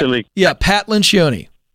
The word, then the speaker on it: Lencioni: Pat Lencioni.